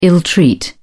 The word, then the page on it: ill treat: British English